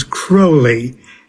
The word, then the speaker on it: Crowley: Well, the way Aleister Crowley pronounced it was Crowley, and he said it rhymes with holy.